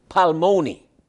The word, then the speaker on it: Palmoni: The word certain saint is a Hebrew word, palmoni. I'm going to spell it for you. P-A-L-M-O-N-I.